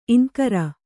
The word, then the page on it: ಇಂಕರ: iŋkara